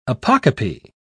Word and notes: apocope: British English

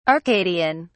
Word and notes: arcadian: British English

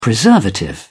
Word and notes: preservative: British English

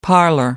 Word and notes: parlor: British English

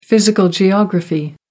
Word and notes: physical geography: American English

